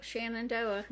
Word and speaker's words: Shenandoah: Trevor made the football team at, I'm sorry and I'm going to butcher this, Shenandoah, sure. – I don't know. – Shenandoah sounds... – Yep, Shenandoah.